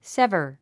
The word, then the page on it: sever: sévə r; セバァ